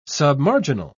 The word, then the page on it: submarginal: British English